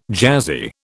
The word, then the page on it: jazzy: British English